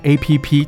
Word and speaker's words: app: 好，那刚才有提到就交友 APP，其实我们常会讲说 APP，可是外国人搞不好听不太懂什么叫 APP。其实 APP 要怎么念比较正确？叫做 App。